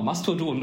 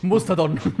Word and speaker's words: Mastodon: Genau, Mastodon, du hast es sogar richtig gesagt. Ich bin, glaube ich, manchmal durcheinander gekommen mit den A's und O's in diesen Namen, aber... – Musterdon. ich weiß gar nicht mehr, was mein Fehler war, aber ich habe es mal falsch ausgesprochen. Wir müssen ich die, äh, die Folge nochmal anhören. – Aber genau, Mastodon ist der, der richtige Name.